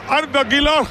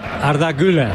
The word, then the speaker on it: Arda Güler: Arda Güler. – Arda Güler.